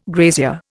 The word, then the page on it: grazier: Indian